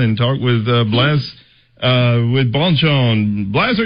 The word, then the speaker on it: Bonchon: Okay, with my Alabama dialect and all that, I want to make sure I'm saying your name correctly and Bonchon.